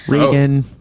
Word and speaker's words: Reagan: Henry and Frank Regan or Reagan from Blue Bloods? – Reagan, Reagan, she says authoritatively.